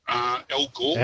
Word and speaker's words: Gore: It rhymes with floor, and he's got a very short name. – Al Gore.